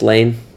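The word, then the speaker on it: Llane: It's spelled Lillane, L-L. – Yeah. – But it's just Lane, like Lloyd is just one L.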